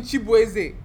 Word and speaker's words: Chibweze: I used to say my name was Chibwez. No, this is what I'm saying, Chibweze. Like, there's bass and, like, I'm doing the intonation and stuff.